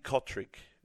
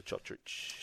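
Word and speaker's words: Cotric: Breaking news, Nick Kotrick wants to be known as Nick Kotrick again. So forget the Chotrich.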